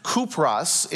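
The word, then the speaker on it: Kupros (Κύπρος): It's actually pronounced Kupros in the Greek, but it's Cyprus, because the winds were against us.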